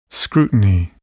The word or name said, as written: scrutiny